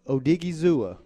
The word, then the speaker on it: Odighizuwa: Odighizuwa.